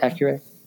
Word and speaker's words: Accuray: Am I pronouncing that correctly? Yep. Accuray. Awesome.